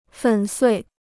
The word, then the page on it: 粉碎: fěn suì